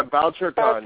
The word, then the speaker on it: Bouchercon: I have been to, is it Bouchercon or Bouchercon? How do we say that? – Yeah, Bouchercon, yes.